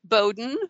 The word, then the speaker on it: Bowden: My name is spelled Z-A-N. So it's pronounced Zan. It rhymes with fan. And my last name is Bowden, which rhymes with snowed in, and it's spelled B-O-W-D-E-N, which I tell people it's bow like what little girls wear in their hair, and den like where a bear lives.